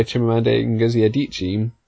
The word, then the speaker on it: Chimamanda Ngozi Adichie: and that was Americana by Chimamanda Ngozi Adichie.